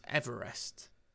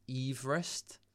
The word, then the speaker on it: Everest: But not only that, we've also been pronouncing it wrong this entire time, because Georgie Boy, he actually objected to the mountain being named after him on the grounds that the native population mispronounced his name as Everest. – Oh, yeah. – His actual name was pronounced Everest.